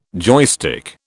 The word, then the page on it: joystick: British English